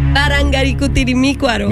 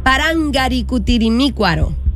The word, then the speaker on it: Parangaricutirimícuaro: Parangaricutirimícuaro. – Me la mataste ahí. – Parangaricutirimícuaro. – No, le faltó una sílaba.